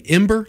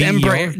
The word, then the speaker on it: Embraer: There's a lot of planes like the, and I may not pronounce it right, I say Embraer, but are you saying Ember, like E-M-B-E-R? – No, it's Embraer. No, it's got an extra I've always just read it and never said it out loud, but the let's see, it's E-M-B-R-A-E-R.